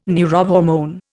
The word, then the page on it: neurohormone: Indian